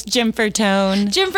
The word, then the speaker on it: Gianfortone: Yes, so close. Gianfortone.